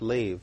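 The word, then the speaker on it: lave (לב): It's the Greek word 3820, L-E-B, but it's pronounced lave, L-A-V. At least maybe it's lave, but there might have been a typo in the concordance.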